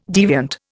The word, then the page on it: deviant: Indian